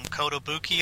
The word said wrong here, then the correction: Kotobukiya: is that right? – Kotobukiya, yeah. – Kotobukiya, okay. Has a Wrecker statue coming, and it was listed as March 2022.